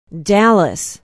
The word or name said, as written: Dallas